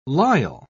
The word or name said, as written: lisle